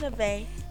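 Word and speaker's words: Nevaeh: It's N-E-V-A-E-H. So whatever y'all want to call it, that's what it is. – It's Neve. – Okay, I'll take Neve.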